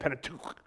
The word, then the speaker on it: Pentateuch: Kind of sounds like a Pentateuch, okay? You can kind of sneeze or whatever it is, but that's how you say it, Pentateuch.